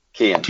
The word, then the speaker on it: Kian: Do you pronounce Kian with a silent nine? – It's Kian. Kian.